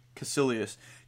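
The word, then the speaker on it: Kaecilius: Cassilius.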